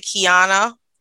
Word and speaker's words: Kijana: So it's actually Kijana. It's K-I-J-A-N-A, but it's pronounced as Kijana.